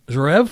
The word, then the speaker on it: Zverev: There's a 27-year-old tennis player, Alexander Zverev.